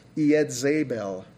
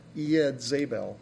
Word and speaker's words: Jezebel: Jezebel, by the way, I've discovered that her name actually is pronounced Yedzebel, Yedzebel, which I thought was kind of interesting, because I always say Jezebel, but...